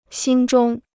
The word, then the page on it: 心中: xīn zhōng